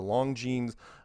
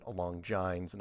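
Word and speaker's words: Longines: It was like the 60 Minutes of his day, called the Long Jeans. I pronounced it wrong. I pronounced it Long Jines, and I had older people calling me up. It's Long Jeans.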